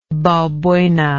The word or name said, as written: balbuena